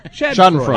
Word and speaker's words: Schadenfreude: Aside from the Schadenfreude that Andy wrote about with on his commentary. – How do you say that word? – Schadenfreude.